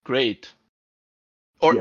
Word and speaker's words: GLEAT: this is one of those things where I will forever call it Gleat, because that's just what my accent wants to call it. Yeah. Now, in Japan, there is no R sound. It's L. So even though it sounds like Greato, or however you want to pronounce it, they've spelled it the proper way you would if you were spelling it using English letters in their katakana.